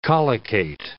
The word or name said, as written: collocate